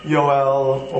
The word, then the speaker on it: Joel: And so Joel would have been pronounced more like Yoel or Yael or something like that.